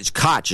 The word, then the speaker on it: Koch: Koch is the way I'd pronounce it, Koch Industry secretly funding climate change machine.